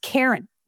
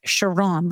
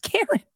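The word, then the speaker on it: Charon: Apparently it's pronounced Karen. Looks like Sharon, but it's pronounced Karen.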